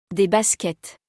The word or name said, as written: Des baskets